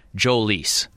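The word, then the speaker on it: Jhoulys: So Royce told me, and not that he is the arbiter of how Venezuelan major leaguers pronounce their names, but Royce said he saw interviews where Mr. Chacín himself said Jolys. Now, what I can't figure out, Rami, is if this is a situation where he's like, well, most of these Americans that cover the game aren't saying this right anyway, so I'll just cave, and it'll be like a two-syllable word.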